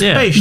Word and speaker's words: Pest: It's Pest. It's actually pronounced Pest.